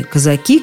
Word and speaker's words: казаки: Рекомендации сводятся к тому, что во всех случаях, кроме одного – Лучше выбирать ударение на окончании «казаки», «казаков», «казакам».